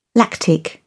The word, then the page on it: lactic: British English